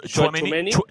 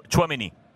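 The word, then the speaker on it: Tchouaméni: Et euh, c'est votre joueur de, Monaco, que je ne sais pas comment le prononcer, mais Chouameni. Chouameni.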